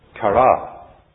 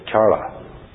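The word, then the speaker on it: chara (χαρά): The Greek word for joy is chara, or I'll say chara.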